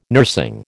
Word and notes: nursing: American English

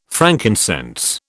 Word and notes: frankincense: British English

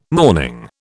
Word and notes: morning: British English

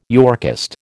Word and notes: Yorkist: American English

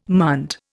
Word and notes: munt: Indian

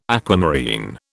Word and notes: aquamarine: British English

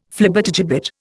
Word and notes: Flibbertigibbet: Indian

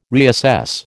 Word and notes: reassess: American English

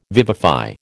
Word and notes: vivify: American English

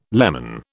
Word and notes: leman: British English

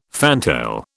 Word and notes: fantail: British English

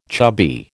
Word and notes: chubby: American English

